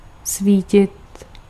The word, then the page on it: éclairer: [e.kle.ʁe]; /e.klɛ.ʁe/